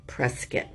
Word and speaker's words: Prescott: In 2008, they settled down in Prescott, Arizona, otherwise known as Prescott, but it's pronounced Prescott.